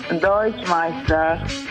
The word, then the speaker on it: Deutschmeister: Deutschmeister.